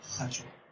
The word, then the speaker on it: qashwa: Es Q-A-S-H-W-A. La Q en el quechua se pronuncia como J. O sea, sería hacho.